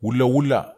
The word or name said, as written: wulawula